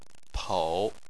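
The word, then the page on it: pou: tone 3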